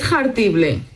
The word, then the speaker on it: jartible: Eso de pronunciar tanto la J ahí de jartible es una H aspirada, ¿eh? Jartible.